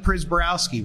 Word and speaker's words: Prziborowski: And then I got Steve Prziborowski, which is how you say his name, not spelled the way you say it.